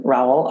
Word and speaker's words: Rowell: So it's Kristin Rowell, R-O-W-E-L-L. And Kristin is spelled K-R-I-S-T-I-N.